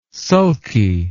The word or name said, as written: sulky